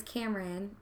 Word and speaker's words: Camaryn: Like, so, my real name is Cameron, but it's spelled C-A-M-A-R-Y-N, so it's spelled different.